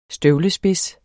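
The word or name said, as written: støvlespids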